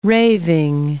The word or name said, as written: raving